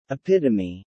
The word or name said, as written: epitome